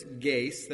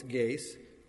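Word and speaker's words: geis (γῆς): Here in the language of the New Testament, Greek, earth is geis. That's how you pronounce that word. It's not cosmos, it's geis.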